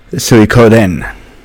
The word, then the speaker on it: Suikoden: And just the way he said it, because I think we all called it Suikoden. Yeah. There's no I. But the way he said it, I was like, oh my god, now I know how to say it.